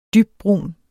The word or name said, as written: dybbrun